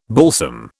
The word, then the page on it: balsam: British English